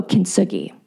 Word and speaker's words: Kintsugi: How do you say this? – Oh, Kintsugi. – Kintsugi yes.